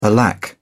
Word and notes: alack: British English

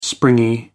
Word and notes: springy: American English

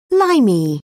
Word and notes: limey: British English

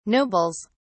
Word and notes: nobles: British English

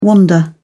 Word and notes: wander: British English

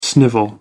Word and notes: snivel: American English

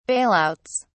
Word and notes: bailouts: British English

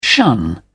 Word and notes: shun: British English

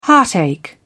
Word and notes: heartache: British English

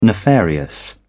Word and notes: nefarious: British English

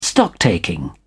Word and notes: stocktaking: British English

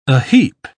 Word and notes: aheap: British English

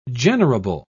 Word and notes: generable: British English

